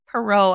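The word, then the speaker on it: Perrot: We did Parrot State Park. Is that how you say it? – Perot, I think.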